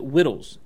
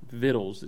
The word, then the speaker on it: Vittles: Because when we read Great Expectations in high school or in middle school, whenever we read it, he pronounces it Whittles, and Charles Dickens writes it that way, Whittles. And there was in our little books a note saying Vittles. It's just the way he's pronouncing it wrong. You know, Vittles is food.